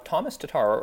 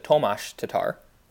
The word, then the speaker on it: Tomáš Tatar: The other weird thing, so Thomas Tatar, or I guess I should say the way Chris Cuthbert says it, Tomáš Tatar, which he doesn't anglicize the name, which I guess is the proper way to say it, was healthy scratched last night.